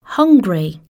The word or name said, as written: hungry